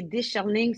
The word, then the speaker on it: Daisherling: So it's spelled like how it sounds. So that's Daisherling.